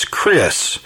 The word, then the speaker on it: Cres: Now, when I say that on the air, I want to spell it because a lot of people think it's Chris, but it's Chris, C-R-E-S, correct? – That's correct.